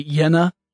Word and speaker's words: Jena: The first one was installed on a rooftop in Jena, Germany. And I apologize. I suppose it would be Jena, Germany. The J is generally pronounced like a Y.